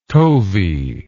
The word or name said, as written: tovey